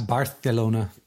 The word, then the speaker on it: Barcelona: I think it's called the Castilian Lisp. – Yes. – Barcelona.